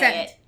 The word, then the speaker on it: Ceant: Is how you say Scent. – It. C-E-A-N-T. – C-E-A-N-T.